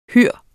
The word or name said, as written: HUR